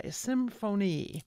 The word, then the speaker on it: Tecnis Symphony: Called a Tecnis Symphony, and I better spell that for you, Tecnis is T-E-C-N-I-S. The symphony part, I'm pronouncing it correctly, S-Y-M-F-O-N-Y, symphony.